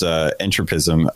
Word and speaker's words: entropism: So Kitsch started, and it was kind of like our 80s and 90s, whereas entropism, I'm still saying that. How do you say that? – Entropism. Yeah, that's right. – Mm-hmm. Entropism? – Like okay. Entropy. Entropism. Mm-hmm.